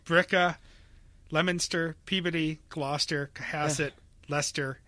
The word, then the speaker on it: Billerica: Bricka is really Bricka. People just drop the L's.